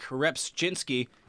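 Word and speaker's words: Rzepczynski: Well, speaking of pitchers, Mark Repchinski, he's back, baby. – Zep. – Is it Repchinski or Repchinski? Yeah. – It's like jogging. The J is silent.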